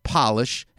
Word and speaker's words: Polish: What I also love about English is P-O-L-I-S-H, polish, right? When the letter P is small case, it's to polish copper or brass. But once you capitalize polish and make it a capital P, you now pronounce it Polish, and it means people from Poland. Same word.